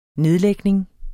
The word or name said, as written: nedlægning